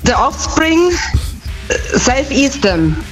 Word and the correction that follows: Self Esteem: Ja, die Aussprache ist ein bisschen... – Steam, ist Steam. – Ja. Ja, ist richtig. – Ja, Self Esteem nehmen wir auch.